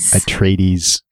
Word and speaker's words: Atreides: You'll make Paul Atreides upset. – Atreides.